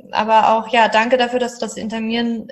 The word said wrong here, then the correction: intermittierende: inter, jetzt kann ich es gerade nicht aussprechen, das Fasten. das, das, intermittierende Fasten.